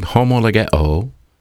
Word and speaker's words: homologeo: The word confess in the original New Testament language of the Greek, Koine Greek dialect, if we confess, is a compound verb, homologeo, is the way it's pronounced.